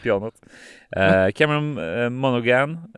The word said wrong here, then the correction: Monaghan: eh, Monahan eh, ja.